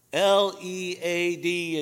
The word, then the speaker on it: lead: and it would say L, E, A, D, and you'd read in parentheses, it would give you the pronunciation, lead, as in, I will lead you to a new place.